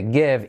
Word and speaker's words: give: To give out, cuidado, I como E, give, I semiclave V, give, give, give out, repartir.